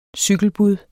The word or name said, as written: cykelbud